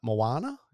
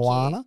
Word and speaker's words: Moana: Like, and then – did that kind of happen, or did Moana? Did I say it right then? – You did. Thank Moana.